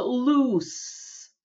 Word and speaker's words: loose: L-O-O-S-E. Произносится loose, не z, а с.